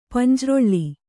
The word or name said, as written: ಪಂಜ್ರೊಳ್ಳಿ